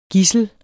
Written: gidsel

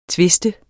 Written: twiste

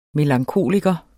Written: melankoliker

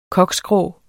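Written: koksgrå

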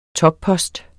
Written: toppost

